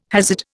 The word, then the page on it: Hasid: Indian